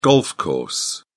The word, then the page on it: golf course: British English